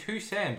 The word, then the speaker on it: Hoisin: Yeah, it's Hussein, but it's spelled Hoisin.